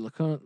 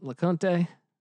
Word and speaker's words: LeCounte: Their star defensive back, Richard LeCounte. – LeConte? I think it's LeConte. – Yeah. I'm butchering that.